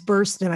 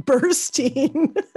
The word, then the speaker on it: Burstyn: Yeah, see, I'm not a fan either, which is why I haven't even been saying her name right. It's Burstyn. I think I've been saying Burstyn.